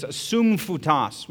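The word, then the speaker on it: sumphutas (σύμφυτος): You know, in Greek, that word is sumphutas, would be the phonetic pronunciation.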